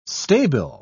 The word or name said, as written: stabile